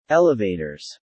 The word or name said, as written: elevators